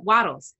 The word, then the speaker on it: Wattles: Wilton Wobble, Wobbles, did I say that right? Yes. Uh, Wattles, that's it.